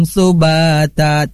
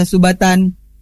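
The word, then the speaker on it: subata (سباتا): Contoh, waja'alna naumakum subata. Tidak boleh dibaca subatan.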